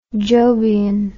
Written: jobian

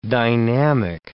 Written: dynamic

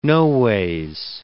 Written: noways

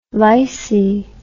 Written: lysi

